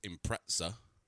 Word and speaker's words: Impreza: Still is pronounced Impreza, because I think that's how everyone pronounces it.